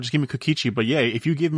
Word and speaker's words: Kikuchi: Emphasis on the syllable, on the first syllable. – Mm-hmm. – Kikuchi.